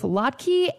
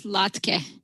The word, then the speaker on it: latke: She says both latke and latke are American pronunciations of the original Yiddish word. – In Yiddish, the word is latke with a kind of a short E. In English, we don't have that same vowel in a final syllable. Instead, we have either a or e.